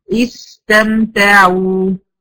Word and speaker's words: استمتعوا: ان انا اطبق بقى القراءة بتاعتي، هخلي زمن السين اطول من زمن ايه؟ الميم. استمتعوا، استمتعوا، استم، استم، استم، تمام كده؟